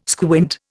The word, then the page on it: squint: Indian